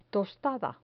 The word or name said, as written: tostada